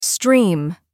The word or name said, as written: stream